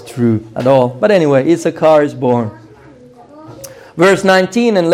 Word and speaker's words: Issachar: But this son, the ninth one, Issachar, it sounds like the word wages, Sakhar.